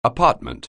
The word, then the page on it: apartment: anglais